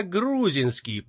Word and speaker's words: Грузинский: «Это Андрей Грузинский, инженер». – «Я грузинский», — поправил его тот усталым тоном. Казалось, что поправляет он директора не первый и не 10-й раз. – «Грузинский! Ударение на «у».